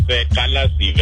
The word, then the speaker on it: Qalazive: I think it's Qalazive.